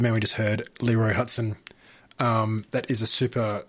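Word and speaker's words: Hutson: Before that, we had Leroy Huxton. Hudson, if I can say it correctly.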